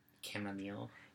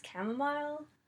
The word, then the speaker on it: chamomile: And I've always pronounced it as chamomile. – So... – I think this... – We just looked it up. Apparently they're both right. So if you pronounce it either way, it's fine.